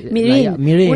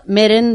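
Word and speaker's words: Meereen: Pues Osuna es Mirin, a ver cómo lo pronuncian, cómo es Mirin. Unos Mirin y bueno, los que han leído los libros Meren de toda la vida.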